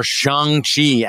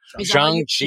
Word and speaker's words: Shang-Chi: We've got Shang-Chi and the Ten Rings, or Shang-Chi, as I found out how it's pronounced these days. Shang-Chi.